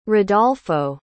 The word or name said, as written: rodolfo